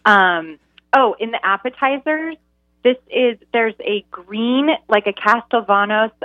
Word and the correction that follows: Castelvetrano: olive tapenade, Castelvetrano. I always say it wrong. Castelvetrano olive tapenade, like those really buttery green olives.